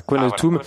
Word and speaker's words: Qualatum: Vous avez monté une chaîne YouTube qui s'appelle Qualatum. Est-ce que je prononce bien ? – C'est comme ça que ça se prononce. – Ah voilà, Qualatum,